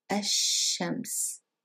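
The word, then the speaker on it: ash-shams (الشمس): So we said that because sheen is a sun letter, we don't pronounce the lam and we double the sheen. So, ash-shams. Very good.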